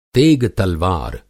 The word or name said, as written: तेग-तलवार